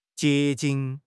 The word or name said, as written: 结晶